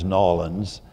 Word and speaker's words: New Orleans: So wonderful to be here, and without further ado, Vicky, growing up in a place that I used to call New Orleans, and then I learned to pronounce as Nolens, you were ensconced in a deeply Catholic family and culture there.